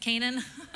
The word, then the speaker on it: Canaan: Did I say that right? I always think I'm saying Canaan. Is it Canaan? Okay, Canaan, who reigned in Hazor.